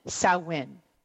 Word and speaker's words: Samhain: Okay, Karen says Samhain. Samhain. Thank you, Karen, because I'm not real good with this. I'm bad at pronouncing it. – Why Samhain? Why couldn't it be Samhain? – Samhain. – How do you get Sow? S-O-W is so. – It's Celtic. You never say it the way it looks.